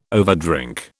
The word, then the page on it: overdrink: British English